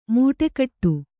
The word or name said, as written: ಮೂಟೆಕಟ್ಟು